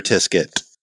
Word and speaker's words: Tisket: I do remember Tisket. – Rhymes with biscuit.